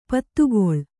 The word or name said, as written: ಪತ್ತುಗೊಳ್